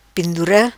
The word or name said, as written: Pendura